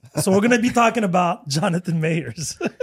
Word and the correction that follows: Majors: He's Jonathan Mayers? – Majors. So, Majors. – Yeah, Majors, whatever, however you pronounce his name.